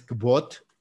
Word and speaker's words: Kvothe: Este, otro personaje también de libros que me encanta y que va muy de la mano también con esto es, es Kvot, se escribe K-V-O-T-H-E, que es el personaje principal de The King Killer Chronicles, que se me hace también maravilloso.